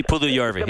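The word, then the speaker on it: Puljujärvi: I just wanted to ask about, okay, I can't pronounce his name right, Puljujarvi. – Oh, yes, he Puljujarvi.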